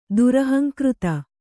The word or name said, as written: ದುರಹಂಕೃತ